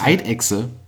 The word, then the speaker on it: Eidechse: Spricht man doch als normaler Mensch aus wie Ei und Dechse, aber es ist ja eine Echse, also heißt es ja höchstwahrscheinlich Eidechse.